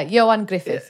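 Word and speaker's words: Ioan Gruffudd: I even Googled the pronunciation of Johan Griffith. – Yeah. – Is that right? – Yeah, Johan Griffith, yeah.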